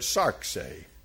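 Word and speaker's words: sarx (σάρξ): it's from the Greek word sarxe. It is sigma, alpha, rho, xi. And you pronounce it sarx. Sarx.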